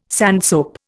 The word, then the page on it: sandsoap: Indian